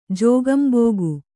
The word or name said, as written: ಜೋಗಂಬೋಗು